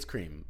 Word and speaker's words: Creme: Now, is it creme? – It's cream, but it's spelled C-R-E-M.